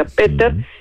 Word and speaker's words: Peter: Por ejemplo, el jueves está el filósofo más importante vivo de la actualidad, que es alemán y que se pronuncia Peter, porque los alemanes se pronuncia Peter, sí.